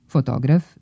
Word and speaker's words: fotograf: Fotograf ne može biti fotograf, jer ova imenica nema akcenatski dublet, već se izgovara sa kratko uzlaznim akcentom na drugom slogu.